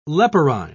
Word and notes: leporine: British English

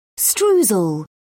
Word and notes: streusel: British English

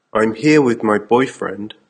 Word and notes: I'm here with my boyfriend: American English

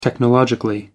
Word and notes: technologically: American English

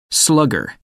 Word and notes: slugger: American English

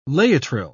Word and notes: laetrile: British English

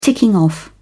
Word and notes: ticking off: British English

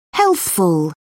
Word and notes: healthful: British English

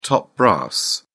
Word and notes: top brass: British English

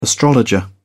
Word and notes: astrologer: British English

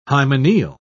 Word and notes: hymeneal: British English

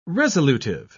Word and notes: resolutive: British English

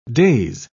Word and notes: days: British English

